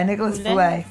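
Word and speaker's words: Feuillatte: Filet. – Filet? – Yeah, Nicholas Filet. Filet. Yeah, Filet.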